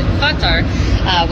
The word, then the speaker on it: Qatar: I think you pronounce it a few different ways, but the fans that I talk to pronounce it Qatar, which is closer to the Arabic.